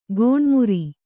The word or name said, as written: ಗೋಣುಮುರಿ